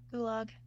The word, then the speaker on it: gulag: If you ever wonder how perfectly ordinary, decent people could find themselves doing the terrible things that the gulag, is that how you say it? – Gulag.